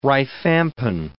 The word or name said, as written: rifampin